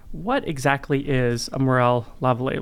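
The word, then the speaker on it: Morel-Lavallée: I think the French is Lavallee, but we call them Morel Lavallee lesions.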